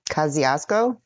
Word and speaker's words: Kosciuszko: He had Colonel Kosciuszko was his engineer down there.